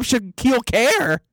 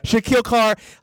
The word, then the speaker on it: Shaquille Carr: You just called him Shaquille Care or something like that. – Shaquille Carr? – Yeah. Okay, all right. Okay. Shaquille Carr.